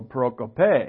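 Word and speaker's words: prokope (προκοπή): See the little accent on the end? That means you go prokope.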